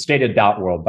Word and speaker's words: Data.World: It's Data.World, by the way. Yep. I always pronounce it that.